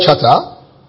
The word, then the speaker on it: chata: The verb is the word chata, again, but it's spelled C-H-A-T-A.